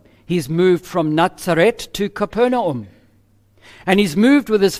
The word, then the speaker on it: Capernaum: In fact, it's pronounced Capernaum in Hebrew.